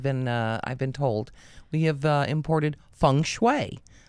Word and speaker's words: feng shui: So, today we're going to talk about the elements and how they figure into feng shui.